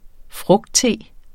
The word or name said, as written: frugtte